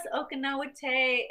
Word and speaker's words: Okinawa Te: So, so this started back at the Okinawa Te Karate, right? – Yes. – Did I say that right? – Yes, Okinawa Te.